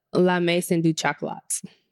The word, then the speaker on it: La Maison du Chocolat: It's called Le Maison du Chocolat. Did I say that right? I would say La Maison du Chocolat. – I should go try to put a little more emphasis on the chocolates. I said that right. I'm trying to hate on me. – They don't say it. It ain't an E at the end.